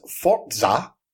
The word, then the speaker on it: Forza: Right, so first story comes from Little Pretty Flair, and that is TIL Forza is pronounced Fortza, according to Forza's Twitter page.